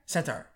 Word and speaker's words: centaur: You just said the right word and then you said it wrong. – What is you centaur. Just said senator. I'm like, senate, a centaur that's also a senator, a senator. – What is the word? – You said it right the first time, centaur. Center. Now you're saying center, like center for disease control.